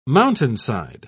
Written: mountainside

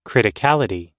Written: criticality

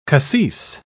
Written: cassis

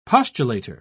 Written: postulator

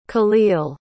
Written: khalil

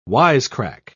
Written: wisecrack

